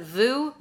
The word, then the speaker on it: Vue: In the 50s and 60s, Vicky worked for, I'm going to pronounce it Vue, V-U-E, Vue magazine as a photojournalist. – I think it's Vue. I pronounce it Vue. – Vue. – Yeah. – Vue. Either way, it's French.